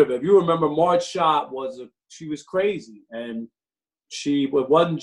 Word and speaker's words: Schott: And the renaming of Marge Schott, is that how you pronounce her name? – Yeah, Marge Schott.